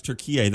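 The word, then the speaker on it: Türkiye: And, yeah, it's Turkey. That's how you pronounce Turkey now, just to let you know.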